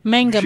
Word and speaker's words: Mangum: Mangum, Oklahoma. – Mangum. – She said it. Yeah. – M-A-N-G-U-M.